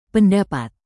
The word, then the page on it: pendapat: Indonesian